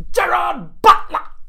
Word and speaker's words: Gerard Butler: And the star of this movie is Gerard Butler. Or, as it should be pronounced, Gerard Butler!